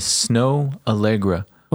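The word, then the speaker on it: Snoh Aalegra: Anyway, again, even though you just heard it, her name is Snow Allegra.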